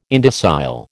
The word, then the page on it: indocile: American English